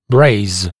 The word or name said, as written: braze